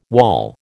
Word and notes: wall: American English

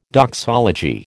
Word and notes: doxology: American English

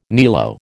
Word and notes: niello: American English

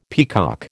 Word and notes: peacock: American English

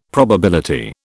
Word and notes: probability: British English

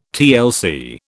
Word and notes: TLC: British English

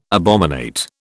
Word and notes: abominate: British English

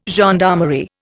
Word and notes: gendarmerie: Indian

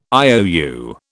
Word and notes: IOU: British English

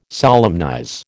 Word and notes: solemnize: American English

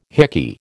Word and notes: hickey: American English

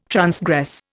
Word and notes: transgress: Indian

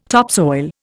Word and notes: topsoil: Indian

